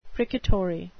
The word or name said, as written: precatory